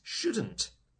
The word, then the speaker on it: should: Notice the pronunciation of should and shouldn't. It's spelt with an L. S-H-O-U-L-D. But the L is silent. We don't say it.